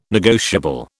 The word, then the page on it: negotiable: British English